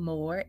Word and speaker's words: Moore: Okay, so Moore Flava Catering is our last name, Moore, M O O R E, Flava, F L A V A, and Catering.